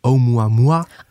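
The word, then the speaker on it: Oumuamua (ʻOumuamua): En dat gebeurde ook bij Oumuamua. – Oh, spreek je dat zo uit? – Oh ja. Ja, ja, ja het is een beetje een gek woord. Er staat ook nog een apostrof aan het begin.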